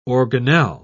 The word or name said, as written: organelle